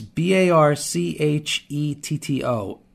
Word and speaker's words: Barchetto: And folks, it's it's B A R C H E T T O. it, if you're Italian, it's pronounced Barchetto, not Barchetto, not Barchetto, Barchetto.